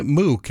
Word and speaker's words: Muq: New music on a New Music Monday from an artist by the name of John Mook, and it's spelled M-U-Q, Shake Shake, Dance For Me.